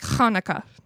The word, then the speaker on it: Hanukkah: But like Hanukkah, that's how you say Hanukkah.